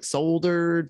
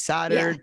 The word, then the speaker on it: soldered: I understand, but I'm just saying, I like the word because it's spelled like soldered, but it's soldered, but you know what it is, but you feel a little smart.